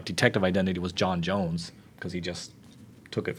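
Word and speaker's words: J'onn J'onzz: Well, that's how it's spelled with the apostrophes in there and the Z's, but I thought the clever bit of it was always it's pronounced John Jones. – I remember being like 4 and my dad being like, it's pronounced John Jones because there's a apostrophe.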